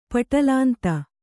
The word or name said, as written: ಪಟಲಾಂತ